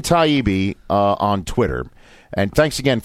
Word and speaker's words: Taibbi: Everybody should go check out his Taibbi blog, right? Is that how I would pronounce that, right? – Yep.